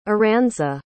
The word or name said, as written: Aranza